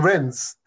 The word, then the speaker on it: Rennes: I saw linked to Rennes. That the initial plan was him to go back to France, but that fell through. – People are really pronouncing the S in Rennes,